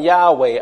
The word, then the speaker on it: Yahweh: So Jehovah and Yahweh are different theoretical pronunciations for God's Hebrew name. Either way, it's the same name, just different ways of saying it.